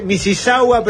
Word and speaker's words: Mississauga: Se escribe Mississauga, pero se pronuncia sin la U.